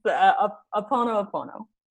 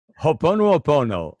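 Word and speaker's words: Ho'oponopono (Hoʻoponopono): I think it's time to play Hoppin'Oppin'Ol. Did I say it right? – That was the best. Ho'oponopono. – Ho'oponopono.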